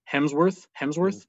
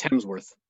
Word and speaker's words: Hemsworth: Am I saying his name wrong? Chris Hemsworth? – Hemsworth? – Yeah, no L. – I think you're adding an L to it. – Whatever. Chris Hemsworth.